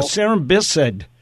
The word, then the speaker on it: cerambycid: Cerambycid. – Oh, cerambycid.